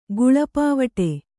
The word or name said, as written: ಗುಳ್ಳಪಾವಟೆ